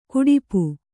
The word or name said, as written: ಕುಡಿಪು